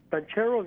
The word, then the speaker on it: Banchero: And Mr. Banchero, is that how you pronounce his name? – Yes.